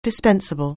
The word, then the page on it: dispensable: anglais